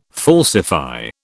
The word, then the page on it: falsify: British English